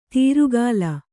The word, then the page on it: ತೀರುಗಾಲ: tīrugāla